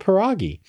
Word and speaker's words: pierogi: And the first thing we learned was how to pronounce this name. And it's called pierogi.